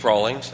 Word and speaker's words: Rawlings: My name is Steve Rawlings. That's spelled R-A-W-L-I-N-G-S.